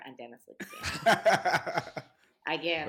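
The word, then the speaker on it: Lyxzén: if Karen O and Dennis Lixon had a, sorry, is it Lixon? Is that how you say it? – Yeah, Lixian.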